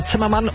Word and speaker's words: Zimmerman: Gerade gefragt, wie Zimmermann eigentlich auf Englisch ausgesprochen wird. Zimmerman. Finde ich mal noch raus. Zimmermann ist aber ansonsten auch absolut korrekt, weil es wird... Nee, es ist Zimmerman.